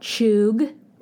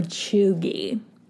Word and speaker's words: cheugy: So the word is called choog or choogy. So it's spelled C-H-E-U-G-Y or C-H-E-U-G.